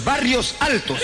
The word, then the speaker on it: Barrios Altos: Y acá, en este barrio de acá, en Ovarios Altos. – ¿Cómo? – Ovarios Altos. – Oye, oye, serrano, no se dice Ovarios Altos. – ¿Qué, no? – Son los barrios altos.